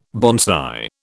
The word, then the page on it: bonsai: British English